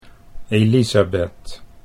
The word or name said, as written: Elizabeth